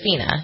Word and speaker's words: FINA: It's Fina.